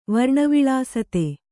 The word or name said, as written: ವರ್ಣವಿಳಾಸತೆ